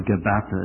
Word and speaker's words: Gabbatha: Gabbatha, or Gabbatha as some pronounce it, the physical.